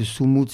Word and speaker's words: sumud: Je rappelle que Soumout, c'est ce mot fondamental qui s'écrit S-U-M-U-D, qui concerne les Palestiniens et qui nécessite plusieurs verbes pour le traduire en français.